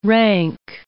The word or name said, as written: rank